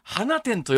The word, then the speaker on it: 放出: あの、放出と漢字で書いて、花店と読むんです。